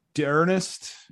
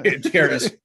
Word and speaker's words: D'Ernest: D'Ernest. I think I pronounced that right. – Yeah. D'Ernest Johnson, I believe.